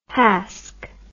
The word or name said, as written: hask